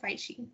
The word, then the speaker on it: phishing: Bueno, es PH, pero se pronuncia phishing, creo.